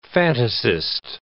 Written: fantasist

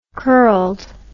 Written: curled

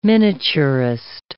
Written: miniaturist

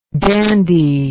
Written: dandyishly

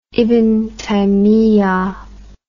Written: ibn taymiyah

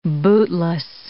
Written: bootless

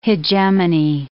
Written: hegemony

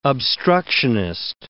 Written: obstructionist

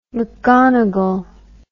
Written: mcgonagall

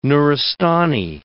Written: Nuristani

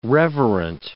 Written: reverent

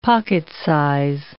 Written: pocket-size

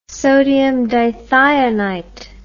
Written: sodium dithionite

